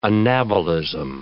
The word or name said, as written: anabolism